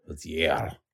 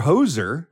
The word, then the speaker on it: Hozier: Some people, it's H-O-Z-I-E-R. Some people think it's Hozier. – Hozier. – Or Hozer. – Uh-huh. – But it's actually Hozier, as in rhyming with cozier or noisier.